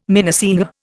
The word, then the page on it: minnesinger: Indian